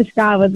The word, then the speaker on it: Scavas: Esattamente, Scavas, con l'accento sulla A, esatto. L'hai detto benissimo, Cosimo.